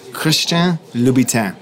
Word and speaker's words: Christian Louboutin: Christian Louboutin.